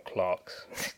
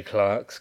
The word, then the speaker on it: Clerks: Obviously, if you know about them, it was Clerks that they were first introduced, or Clarks. I probably should say Clarks because I'm a Brit, but most people just call it Clerks. It's spelled with an E, so what's up with this language?